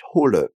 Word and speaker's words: hole: Quando ele diz que vai tirar, ele falou ich hol. Ich hol. A expressão bem certinha do Hochdeutsch seria ich hole. Ich hole. Com E no final.